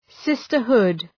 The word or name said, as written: sisterhood